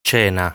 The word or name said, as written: Cena